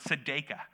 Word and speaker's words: tzedakah: Actually, righteousness to a first century Jewish mind is the word tzedakah. Like Jason Sudeikis from Ted Lasso, that's how I remember how to pronounce it.